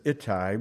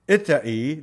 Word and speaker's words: Ittai: I know everybody says Ittai, but it's Ittai, the Gittite.